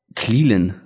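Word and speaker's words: Clelin: Cleland wird er ausgesprochen, ne? Ich habe ihn falsch ausgesprochen.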